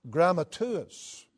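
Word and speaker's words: grammateus (γραμματεύς): Then you come to the New Testament, looking at the Greek word for scribe, and it is pronounced grammateus.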